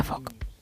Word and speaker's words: folk: Por cierto, para el doctor, que era el que ponía caras y estaba negando con la cabeza, la pronunciación fonética correcta de folk, que bueno, puede significar gente, pueblo, el género folclórico, tiene lo siguiente. ¿Qué estás apuntando? – No, no, no. – Bien. Su regla clave es que la letra L es muda. – Anda. – Con lo cual se pronuncia folk.